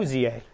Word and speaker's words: Awuzie: How do we pronounce that? – Awuzie. Yeah.